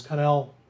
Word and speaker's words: Connell: And Kelli Cannell. Oh, yeah, me too. These images were created, as Connell Connell, I guess is correct pronunciation, Kelli Connell.